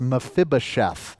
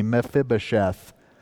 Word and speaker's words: Mephibosheth: It's Mephibosheth. Everybody say that with me, Mephibosheth.